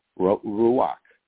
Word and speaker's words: ruach: The term spirit in the Old Testament is the Hebrew word ruach, or is spelled R-U-A-C-H, which is also commonly translated wind and breath.